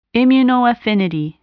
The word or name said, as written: immunoaffinity